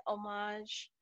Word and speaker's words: homage: So why not use this episode just to pay homage to, is that how you say it, homage? – I think so.